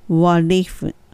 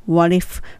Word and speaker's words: what if: ここの t はどちらかというと air に近い発音になるので歯の裏にベロの先をちょっと当てて what if what if f は f っていうふうに息が漏れるように上の歯を下の唇にちょっと当てて間から息が漏れる感じ What if?